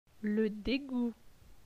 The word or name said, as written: Le degout